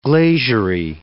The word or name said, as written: glaziery